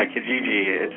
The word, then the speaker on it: Kijiji: Yeah, Kijiji. It's eBay's own classified site, free and family friendly. It actually means village in Swahili. – Okay. And how long has Kijiji been around on eBay?